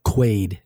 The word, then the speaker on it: Quaid: Quaid, I don't know how they're going to do it. What's that? – Quaid, not Wade.